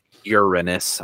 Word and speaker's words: Uranus: Uranus, I guess, technically, is how it's pronounced.